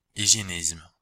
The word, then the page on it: hygiénisme: Belgique, Brabant wallon; [iʒjenizm]